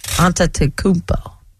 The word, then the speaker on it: Antetokounmpo: And it starts with showing who are you today when you are in Milwaukee against those Bucks and Giannis Antetokounmpo. I always have a terrible time. You say it for me. Go ahead. – Antetokounmpo.